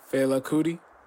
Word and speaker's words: Fela Kuti: Did we talk about Fela Kuti? Is that how you say his name? – Fela Kuti? – Yeah.